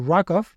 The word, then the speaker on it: RWKV: Y por último, eh, me ha parecido también relevante mencionar eh, un nuevo modelo fundacional que se llama RWKV, según ellos se pronuncia algo así como RAKOV